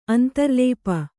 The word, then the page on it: ಅಂತರ್ಲಾಪಿ: antarlāpi